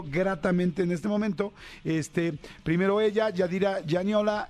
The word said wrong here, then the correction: Gianola: Gianola, eh, ¿lo dije bien? – Gianola.